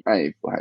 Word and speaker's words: Ive: 之前苹果的首席设计师就是这一位 Johnny Ive，艾弗还是 Eve？应该是艾弗。